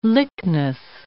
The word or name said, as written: lychnis